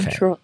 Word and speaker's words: throw: Throw is hard to pronounce because the TH. – Yes. Throw. So try this. So it's ROW. Look at the THROW, right? You cut it in the middle. So TH and then ROW.